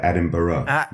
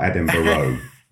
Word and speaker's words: Edinburgh: That's better. Edinburgh, not Edinburgh, which is right.